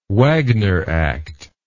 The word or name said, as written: Wagner Act